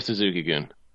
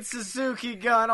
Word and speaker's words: Suzuki-gun: You have Suzuki-Goon. – Well, fuck. – Oh, is it Goon? I've been saying Suzuki-Gun all night, and I know I'm not the best at pronouncing Japanese names, but fuck.